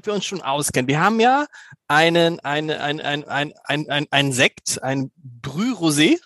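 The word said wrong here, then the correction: Brut Rosé: richtig ausgesprochen? – Brüt-Rosé.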